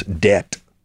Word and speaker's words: debt: It's very easy, but in English, we have many silent letters. Debt. We don't say debt, Right.